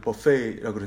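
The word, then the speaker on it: buffet: 제가 오늘 하나님의 뷔페라는 이름을 지었는데 뷔페는 조금 저렴한 발음이죠. 뭐죠? 원래는 영어는 buffet라 그러나요? 예.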